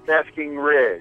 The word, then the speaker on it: Basking Ridge: I live in Florham Park, but right now I'm in Basking Ridge. – How do you say it? Baskin? Is it like Baskin Robbins? – Basking Ridge.